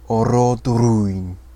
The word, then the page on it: Orodruin: Sindarin